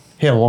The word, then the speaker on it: Gil: It's Gil, right? – Hill.